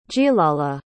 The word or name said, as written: gilala